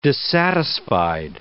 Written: dissatisfied

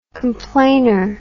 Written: complainer

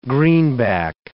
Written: greenback

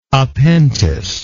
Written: appentice

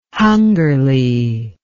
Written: hungerly

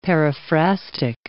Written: paraphrastic

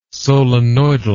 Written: solenoidal

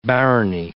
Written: barony

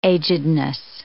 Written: agedness

